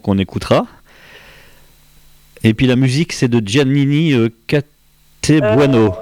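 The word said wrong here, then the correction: Gianni Castelbuono: c'est ça? – Gianni Castelbueno, oui. – Ah là, c'est bien la prononciation.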